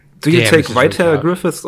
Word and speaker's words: Griffith: I gotta go with my boy, the bussy of Midland, Griffith. – You're saying it wrong. It's actually Griffith.